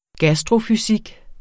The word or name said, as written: gastrofysik